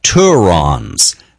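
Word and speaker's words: tourons: The members discussed the Turons, rhymes with morons, a reference to the tourists that continue to visit despite West Texas emerging as a hotspot.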